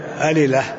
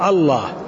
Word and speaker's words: الله: قال العلامة ابن القيم رحمه الله: الصحيح أنه مشتق وأن أصله بلا، هنا تسبق بألف، ما تقول ألله، إنما تقول الله، فالنطق لام واحدة مشددة مفخمة مضاعفة، أي نعم.